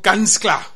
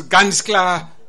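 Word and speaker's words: ganz klar: But there's another concept, it's called Ganzklar. – I'm so happy that you pronounce Ganzklar the way the people do it in Niedersachsen in Germany.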